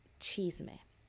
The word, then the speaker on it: chisme: We all know Unholy, but it's chisme. Now, chisme is a Spanish word for gossip, and who doesn't like un poquito de chisme?